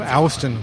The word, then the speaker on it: Houston: It's pronounced Houston, right? No, Houston.